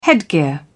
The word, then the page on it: headgear: British English